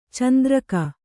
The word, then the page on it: ಚಂದ್ರಕೆ: kannada